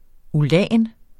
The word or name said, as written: ulan